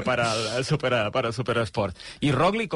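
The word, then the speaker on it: Roglič: Mira, ya que te tengo, Roglic, tampoco sé pronunciar Roglic, ¿cómo lo tenemos que pronunciar? – Roglic. – Roglic, ah, sí, bien. Este sí.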